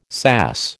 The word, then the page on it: sass: American English